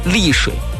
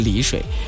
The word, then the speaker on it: 丽水: 我看到小一休发信息了，说早上好，这是我们的李水的朋友啊，不是李水，怎么发音都发成李水了，特地告诉自己那个叫丽水，越想就越发成了李水。李水是浙江的李水，就是浙江有一个美丽的丽，那个地方念的是李水，而我们南京的就是丽水，三点水旁的那个丽水。